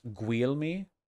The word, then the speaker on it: Guglielmi: It's pronounced Guglielmi, Guglielmi, Guglielmi, Guglielmi.